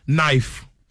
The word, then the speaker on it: knife: These are English words with silent letter. Knife.